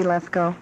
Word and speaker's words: Lethcoe: Yes, my name is Nancy Lethko, and it's spelled L-E-T-H-C-O-E.